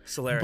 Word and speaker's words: Solaris: Next week, we're actually talking about Solaris? Solaris? I'm not sure how to pronounce it. – Solaris.